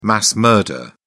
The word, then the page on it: mass murder: British English